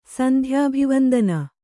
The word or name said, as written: ಸಂಧ್ಯಾಭಿವಂದನ